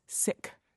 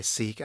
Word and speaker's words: Sikh: I said Sikh, but as I understand it, the proper pronunciation is Sikh. – You're correct. The proper pronunciation is Sikh, but most people in U.S., we'll go by Sikh, and I think either one is acceptable.